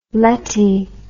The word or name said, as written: letty